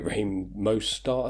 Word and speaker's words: Mostert: Raheem Mostert, I think is how you pronounce his name. Right, yeah. I think he's been performing great, especially last week.